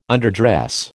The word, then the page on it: underdress: American English